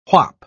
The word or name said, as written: whop